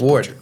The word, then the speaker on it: Ward: I always Ward. – Ward? – Yeah. – Oh, I thought it was like Ward E or something at the end.